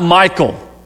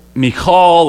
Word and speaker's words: Michal: And if I say that word 1,000 different ways, it's not Michael. It's not Michael, but it's Michal, or I guess you have to be a Hebrew to really pronounce it correctly.